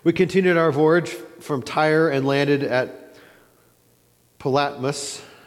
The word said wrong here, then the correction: Ptolemais: Actually, this was the one I even looked up trying to figure out how to pronounce this, and I never figured it out. Ptolemais is what I'm going to say tonight. Ptolemais, where we greeted the brothers and stayed with them for a day.